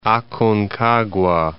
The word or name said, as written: Aconcagua